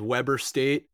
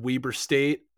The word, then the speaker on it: Weber: It wasn't, no, it's a weird pronunciation. Most people would read that as Weber State. You remembered Weber State.